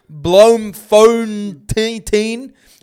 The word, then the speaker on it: Bloemfontein: It has three capital cities, being Cape Town, Bloemfontein and Pretoria. – Pronunciation on point.